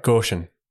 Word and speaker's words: Goshen: I am pronouncing that right, Goshen. That is the right way of pronouncing it.